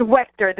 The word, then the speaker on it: Welcher: It's Welcher. The CH is like a K.